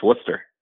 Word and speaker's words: Worcester: And did I say that right? I know a lot of people say Worcester, but I think it's Worcester. All right. – It's Worcester. Yeah, Worcester.